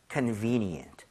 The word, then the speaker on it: convenient: V-E에 강세를 짓고 발음하면 되겠습니다. 그럼 같이 한번 발음 해보도록 할게요. V-E에 강세 주고요. – 네. – convenient.